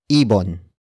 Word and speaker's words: ibon: And that is ibon.